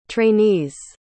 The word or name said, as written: trainees